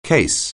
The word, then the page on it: case: anglais